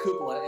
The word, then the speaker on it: Coppola: No, no, no. It's Coppola, and I just said like Francis Ford Coppola.